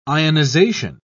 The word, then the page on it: ionisation: British English